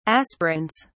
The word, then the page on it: aspirins: British English